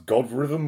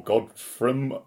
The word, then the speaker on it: Godthrymm: I think is this pronounced Godrhythm or Godfrum? – I would have gone with Godfrum. – Godfrum, spelled GODTHRYMM, who are a very new UK doom band in the kind of trad doom Candlemass style vein.